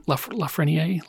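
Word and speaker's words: Lafrenière: Lafreniere? – Yep.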